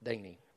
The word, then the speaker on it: Dante: I guess Dante around here would be pronounced Danny, but anyway.